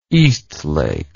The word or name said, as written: eastlake